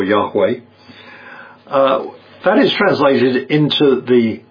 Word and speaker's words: Yahweh: This is talking about Yahweh, sometimes translated Jehovah. More accurately, we think, is pronounced Yahweh.